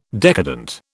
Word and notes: decadent: British English

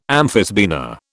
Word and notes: amphisbaena: British English

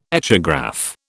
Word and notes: echograph: British English

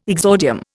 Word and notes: exordium: Indian

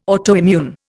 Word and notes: autoimmune: Indian

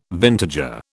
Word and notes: vintager: British English

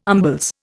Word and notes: umbles: Indian

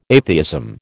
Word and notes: atheism: American English

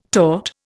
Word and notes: taught: Indian

